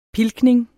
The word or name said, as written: pilkning